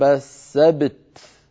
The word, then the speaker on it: السبت: أيضا عندنا في الآية 47 قوله تعالى كما لعنا أصحاب السبت، لما وقفنا عليها سكنت التاء من كلمة السبت وقبلها باء ساكنة، فالتقى في النطق ساكنان، باء ساكنة مقلقلة وبعدها تاء فيها صفتانها مهمتان، صفة الشدة ويتلوها صفة الهمس، فلا بد من بيان شدة التاء وبعدها مباشرة بيان همسها، هكذا كما لعنا أصحاب السبت.